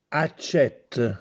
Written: Accètte

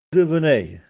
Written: Addevenè

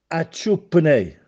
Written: Acciuppenè

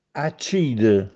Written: Accìde

